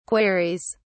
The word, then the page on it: queries: British English